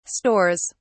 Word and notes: stores: British English

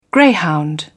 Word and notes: greyhound: British English

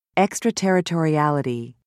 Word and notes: extraterritoriality: American English

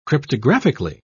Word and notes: cryptographically: British English

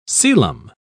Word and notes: celom: British English

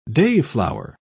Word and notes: dayflower: British English

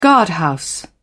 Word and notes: guardhouse: British English

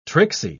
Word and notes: tricksy: British English

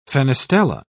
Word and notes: fenestella: British English

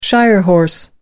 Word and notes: shire horse: American English

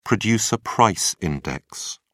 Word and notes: producer price index: British English

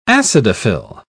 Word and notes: acidophil: British English

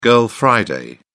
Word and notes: girl friday: British English

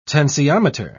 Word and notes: tensiometer: British English

